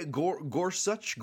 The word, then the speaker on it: Gorsuch: Gorsuch, is that? – Yeah, Gorsuch, Gorsuch.